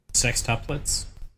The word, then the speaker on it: sextuplets: Well, sex triplets, I think that's how you pronounce it. – Sextuplets? – Yeah.